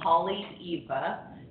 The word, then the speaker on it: Haleiwa: It's in the town of Holly Eva, which is spelled H-A-L-E-I-W-A. There's only 13 letters in the Hawaiian language, so that's why they all sound the same.